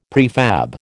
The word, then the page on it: prefab: American English